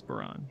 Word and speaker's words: Buran: It's not Buran, it's Buran. – Yeah, exactly.